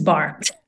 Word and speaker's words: barre: the barre. Is it barre? Is that how they say it? Or bar? – Bar, yes. Bar.